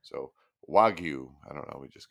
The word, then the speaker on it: wagyu: So Wagyu beef.